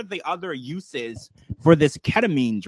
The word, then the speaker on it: ketamine: And we were discussing that we found out that Matthew Perry was on a drug called ketamine. Am I saying that correctly? – I believe so. Yes.